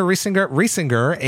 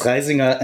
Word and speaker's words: Riesinger: On Sunday, we saw the reveal of Dungeons of Hinterberg, and joining us today are Regina Riesinger and Philip Seyfried. Did I get those? I totally butchered that, didn't I, guys? – It's Riesinger and Seyfried, but okay.